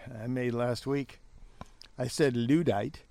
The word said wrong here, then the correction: Luddite: when I should have said Luddite, like you said.